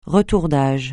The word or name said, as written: retour d'âge